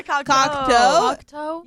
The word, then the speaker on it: Cocteau: Yeah, Cocteau.